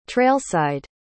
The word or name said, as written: trailside